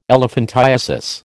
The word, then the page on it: elephantiasis: American English